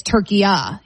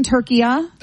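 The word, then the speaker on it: Türkiye: Okay, so it's not what we would call, you and I are making it a hard A, Turkey A, and it's Turkiya, saying. So it's a soft A.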